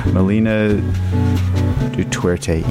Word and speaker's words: Melina Duterte: Jay Som, local singer songwriter Melina, Melinda Duterte. She, um, God, did I pronounce that correctly? I'm really trying to. I apologize. Melina Duterte. Yes, thank you.